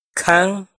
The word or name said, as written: Khaab